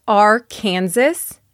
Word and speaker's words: Arkansas: It looks like Arkansas, but the S at the end is silent. So Arkansas.